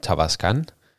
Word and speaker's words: Tavascan: Øhm, og de har, øhm, de har præsenteret en, øh, en Cupra Tavascan, tror jeg den hedder.